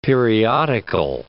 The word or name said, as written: periodical